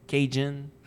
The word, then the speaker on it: Cajun: Cajun, Cadien, Cajun, Cajun. – Ben, le mot Cajun, ce que nous autres, on s'appelait nous-mêmes, provient de Acadien, de la façon que c'était prononcé, le D-I.